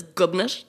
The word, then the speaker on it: Gobnait: so now we just every day refer to the unborn child as Gubnit, which is spelled G-O-B-N-A-I-T and is a legitimate Irish name.